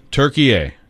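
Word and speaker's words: Türkiye: Turkey A.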